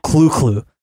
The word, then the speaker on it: Cthulhu: Remember, Lovecraft himself is said to have pronounced Cthulhu as the guttural clu-clu, while the more common ones, Rulieh, Fatang, tend to come out more mangled.